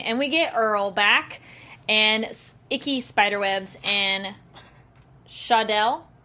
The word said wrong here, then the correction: Shaladel: Did I say it wrong? I, like, wrote it. – Shaladel. – Shaladel. There we go. Extra L.